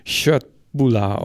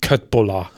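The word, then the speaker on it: Köttbullar: Genau, die spricht man nämlich Schöttbuller aus und nicht Köttbuller.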